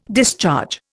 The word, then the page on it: discharge: Indian